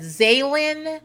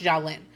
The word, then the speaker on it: Zsalynn: A part of me wants to pronounce that Zaylin. The first part looks like Zsa Zsa Gabor. So Jalen. There are a lot of ways you can pronounce this. But I made sure I watched some videos. I paid attention to the way they were pronouncing it. And I really think it's pronounced Jalen.